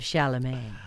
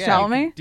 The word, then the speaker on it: Chalamet: And a silent T at the end of Chalamet.